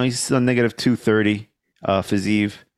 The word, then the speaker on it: Fiziev: I feel Fiziev, like, Fiziev, that's how it's pronounced.